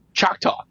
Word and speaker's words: Choctaw: is her connections to Choctaw.